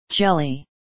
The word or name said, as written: jelly